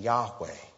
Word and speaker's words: Yahweh: The Hebrew pronunciation Yahweh.